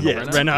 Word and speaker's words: Renault: But it's spelt R-E-N-A-U-L-T, right? – Reno. Yeah, Reno.